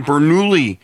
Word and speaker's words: Bernoulli: She was doing Bernoulli. – That's how you say it.